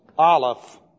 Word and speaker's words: Aleph: And in the Hebrew, it is spelled like this, Aleph. That's A-L-E-P-H.